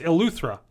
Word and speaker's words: Eleuthera: It is a tropical destination in the Bahamas, which will be located on the beautiful island of Yulthera? How did they say this again? – I don't remember. – Yulthera? – I was waiting Yulthera? To hear you pronounce it. – It's Eleuthera. Eleuthera. – Yeah, that's right.